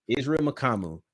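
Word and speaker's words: Mukuamu: Israel Makamu. Yes. – Israel Makamu. Right. Did I get that right? – Yeah, you're good.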